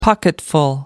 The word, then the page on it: pocketful: American English